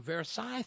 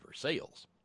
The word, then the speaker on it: Versailles: I mean, there's that whole damn Versailles thing, or as they pronounce it in Kentucky, Versailles.